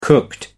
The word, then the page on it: cooked: American English